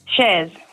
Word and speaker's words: Chers: Shers or Shers? – Shers. – Shers, because the girls here have written your name as C-H-E-R-S. Is that how you spell it? – That's it.